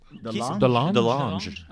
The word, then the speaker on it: DeLonge: DeLonge.